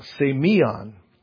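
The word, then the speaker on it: semion (σημεῖον): First, the word signs, it comes from it's a Greek word here that's pronounced semion.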